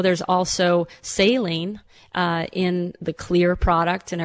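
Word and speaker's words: Xlear: So their X, they spell it the same way. It's spelled with an X. But their X is pronounced like a kind of a soft K. So I took the Finnish X and made clear. So that's why it's spelled with an X, but it's pronounced clear.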